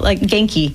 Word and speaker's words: genki: How do you pronounce that? – Genki. – Genki. Genki. – Like with a hard G. Hard G.